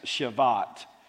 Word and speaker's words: Shabbat: Shabbat. We pronounce the B with the sound V, but the B in the Hebrew is the V.